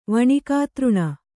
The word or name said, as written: ವಣಿಕಾತೃಣ